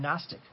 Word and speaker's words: Gnostic: Let's define the term Gnostic. G-N-O-S-T-I-C. It's Gnostic. The G is silent. The G is always silent.